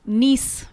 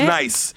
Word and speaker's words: Nice: then next week from the 14th, the 15th to the 18th, I'll be in Nice, France. – Yes. – Oh. Selling my pilot. – It's pronounced nice. It's nice.